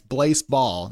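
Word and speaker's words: Blaseball: It's not, I'm not mispronouncing baseball. I'm not dumb. I can read. It's Blaise Ball.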